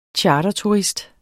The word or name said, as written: charterturist